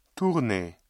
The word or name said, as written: tourner